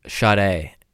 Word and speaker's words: Sade: On the pronunciation, it's Sade. As if it was pronounced Shade.